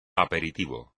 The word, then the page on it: aperitivo: espagnol